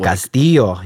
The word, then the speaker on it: Castillo: So you like the double L pronounced that way. – Castillo, yes.